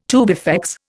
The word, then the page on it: tubifex: Indian